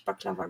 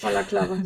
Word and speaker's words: Balaklava: Habe ich es richtig gesagt oder habe ich Baklava gesagt? – Nee, Balaklava war richtig. – Balaklava, super.